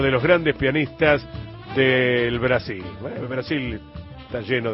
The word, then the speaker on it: Brasil: Ellos dicen Brasil, no como en Portugal que dice Portugal.